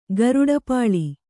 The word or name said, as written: ಗರುಡಪಾಳಿ